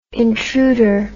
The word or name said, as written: intruder